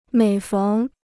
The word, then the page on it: 每逢: měi féng